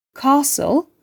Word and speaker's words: castle: STEN veya STLE ile biten kelimeler sessiz bir T ile telaffuz edilir. Fasten, listen, castle ve whistle gibi kelimelerin tümündeki T harfi sessizdir.